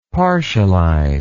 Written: partialize

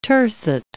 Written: tercet

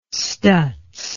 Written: stutz

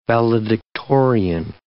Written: valedictorian

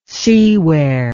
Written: seaware

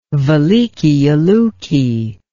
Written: velikiye luki

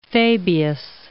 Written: Fabius

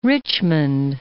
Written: Richmond

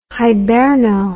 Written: hiberno

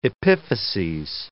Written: epiphyses

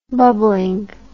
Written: bubblingly

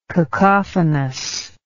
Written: cacophonously

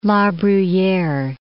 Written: La Bruyere

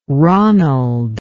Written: ronald

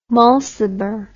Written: Mulciber

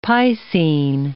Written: piscine

